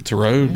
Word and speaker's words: Tureaud: Yeah, anyway, Lawrence Turaud. – Turaud. – T-U-R-E-A-U-D. That sounds French. How would you say that? Turaud? – Yeah. – Turaud.